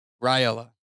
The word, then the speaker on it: Riola: Riola. – Okay. It doesn't look like that when you read it out loud.